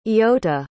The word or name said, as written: eyota